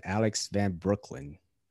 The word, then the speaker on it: Alex van Breukelen: I have none other than Alex Van Breukelen. Did I pronounce that right, Alex? – Yep, you got it right. Breukelen like the bridge in the borough, man.